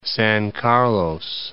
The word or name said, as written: San Carlos